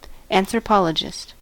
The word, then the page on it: anthropologist: American English